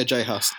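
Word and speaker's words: J Hus: Yeah, Jay Huss.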